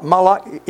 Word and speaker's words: Malach: Moloch, I'll spell it for you. M-A-L-A-C-H and then Jehovah.